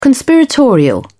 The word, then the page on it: conspiratorial: British English